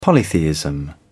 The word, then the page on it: polytheism: British English